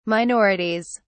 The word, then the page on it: minorities: British English